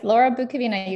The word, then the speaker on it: Laura Bukavina: Laura Bukavina, you got it.